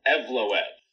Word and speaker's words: Evloev: Mozar Evloev.